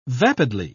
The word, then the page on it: vapidly: British English